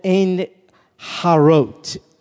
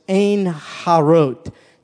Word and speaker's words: Ein Harod: Tonight, I'd like to take you to a place a little hard to pronounce called Ein Harod. Ein Harod.